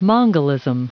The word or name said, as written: mongolism